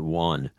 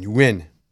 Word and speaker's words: yuan: Here, Chinese pronounce it. They pronounce it Yuan. Yuan. Y-W-E-N. Yuan. Rhymes with when.